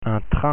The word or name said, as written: train